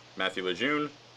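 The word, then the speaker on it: Mathew Lejeune: So you can find me on Facebook, Matthew Lejeune.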